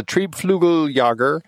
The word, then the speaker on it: Triebflügeljäger: It is a Triebflügeljager, if I'm saying that right. – Very well done.